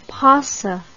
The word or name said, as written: pauci